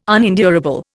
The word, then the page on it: unendurable: Indian